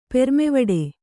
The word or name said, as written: ಪೆರ್ಮೆವಡೆ